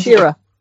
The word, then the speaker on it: Schirra: The hour that follows focuses on Wally Schirra, leader of Shira.